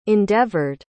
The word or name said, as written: endeavored